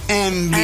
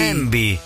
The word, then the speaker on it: Yngwie: Porque su nombre es Envy. Así se pronuncia, Envy. – Envy.